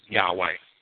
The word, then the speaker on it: Yahweh: Now, I'm going to consider that Josephus's four vowels were I-O-U-E and pronounce it Yahweh. Because there is historical basis for that. – Right, exactly. – It's not historical basis for Jehovah or Yahushua or Yahuwah or anything crazy like that.